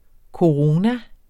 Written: corona

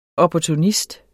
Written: opportunist

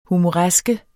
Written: HUMORESKES

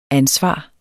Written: ansvar

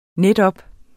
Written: netop

